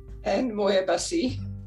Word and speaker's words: Ann Moir-Bussy: Ann Moir-Bussy.